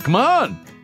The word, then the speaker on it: Kamon: Come on. – Come on, like, come on over here.